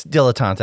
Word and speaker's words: dilettante: Is it dilettante or dilettante? I always pronounce it wrong. – It's dilettante, I think. – Dilettante, that's it.